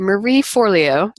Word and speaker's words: Forleo: And Marie Forleo, I butcher her name too. Marie Forleo, is that how you pronounce her name? – Yes.